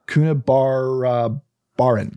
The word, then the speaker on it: Coonabarabran: Now, Bruce was born in September of 1956 to Athol and Joyce Roberts in a small town called, this is a hard one to say, bear with me, Coonabarabran. I think I said that right. – That actually sounded pretty legit. – Coonabarabran.